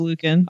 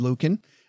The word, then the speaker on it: Luken: Our headline today comes to us from Christine, what do you think? Lukin? Luckin? L-U-K-E-N. – Oh, the long U. – I'd say it's Lukin. – I'm going to go with Lukin.